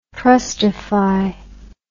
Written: trustify

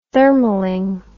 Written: thermaling

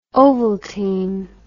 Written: ovaltine

